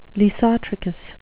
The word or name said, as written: lissotrichous